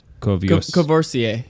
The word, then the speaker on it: Courvoisier: Courvoisier.